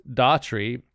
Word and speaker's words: Daughtry: Chris Daughtry, pronounced Daughtry, has already proved Simon Cowell wrong time and time again, and we're only down to the final 12.